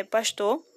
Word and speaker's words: Pasteur: Pastor, é, Pasteur, né, que se escreve Pasteur é, é Pastor, suporta consigo pa os padecimentos de milhões de enfermos e atormentado desenvolve a conquista salvadora contra os perigos do microcosmo, não, microcosmo.